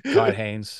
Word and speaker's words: Todd Haynes: Todd Haynes.